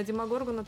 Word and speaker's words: Демогоргон: Как, он, как правильно ударение? Демогоргон? – Ну да, Демогоргон.